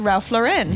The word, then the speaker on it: Ralph Lauren: If that's how you say his name, it's Ralph Lauren.